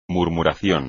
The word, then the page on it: murmuración: Spanish